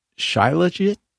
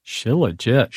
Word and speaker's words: shilajit: shilajit. Shilajit.